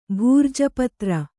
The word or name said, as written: ಭೂರ್ಜಪತ್ರ